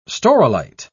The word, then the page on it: staurolite: British English